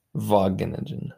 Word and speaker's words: Wageningen: Sorry, it's Vageningen.